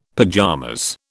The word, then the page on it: pajamas: British English